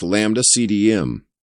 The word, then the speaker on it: Lambda CDM: Lambda cold dark matter, pronounced lambda CDM, the standard cosmological framework, is a phenomenological fit.